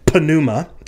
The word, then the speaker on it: pneuma: Pneumatology comes from the Greek word pneuma. Yes, in Greek, you are supposed to pronounce the opening P, pneuma.